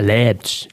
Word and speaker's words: Leipzig: Habe dir, und noch äh, viel Spaß in äh, Lipsch. – Ja, Leipzig, Leipzig, glaube ich, spricht man es aus.